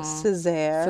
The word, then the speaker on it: Cesaire: that because my married name is Denise Natasha Joy Cesaire. – Oh. – Which everybody seems to like mispronounce. So Cesaire.